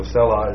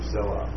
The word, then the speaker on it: selah: So selah is just that's how you pronounce this word in Hebrew, selah.